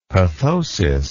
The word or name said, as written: pathosis